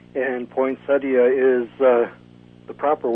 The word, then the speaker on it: poinsettia: He is saying that I have researched this in the past, and it is poinsettia, four syllables, is the proper way.